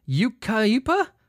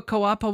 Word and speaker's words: Yucaipa: Are you a Yukaipa Wapa Wapa couple? Y-U-C-A-I-P-A. How the fuck do you pronounce that? Yukaipa? Yukaipa. Couple has been arrested for allegedly causing intentional collisions in an attempt to defraud insurance companies.